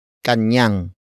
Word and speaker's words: kanyang: First, we have kanyang. Kanyang. Kanyang. Kanyang is spelled as K-A-N-Y-A-N-G.